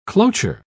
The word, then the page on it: cloture: British English